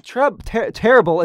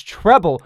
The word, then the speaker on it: treble: Like, no, Dizzy, it's not terrible. It's treble.